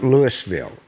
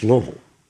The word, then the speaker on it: Louisville: Yeah, okay, so I'm not sure for the Louisville and Colorado, but when you talk about Louisville in Kentucky, it's not Louisville, it's Louisville.